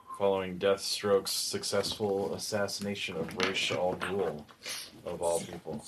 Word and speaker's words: Ra's al Ghul: Ra's al Ghul. – That's the correct way to pronounce it, though.